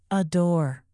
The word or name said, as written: adore